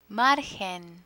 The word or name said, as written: margen